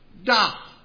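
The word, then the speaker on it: Da (大): That is the Chinese character pronounced Da, which means big or great.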